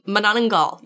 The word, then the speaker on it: Manananggal: Manananggal, yes? – Yes.